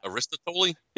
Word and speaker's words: Aristotle: That's not right. But how do you pronounce that? Aristotle? – Yes, that you got it on the second try.